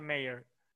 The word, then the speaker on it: Mayer: Eh, según yo es, con A, pero se pronuncia Mayer.